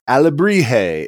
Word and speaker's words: Alebrije: Alebrije is the correct pronunciation. – Okay. – I found out from the man himself. I've been pronouncing it like Alebrije. – Jalapenos the whole time. – Yeah. – Like fajitas, jalapenos. like you know, butchering the most like simple Mexican or you know, Spanish pronunciation you could ever do. It's like the J is like the H sound.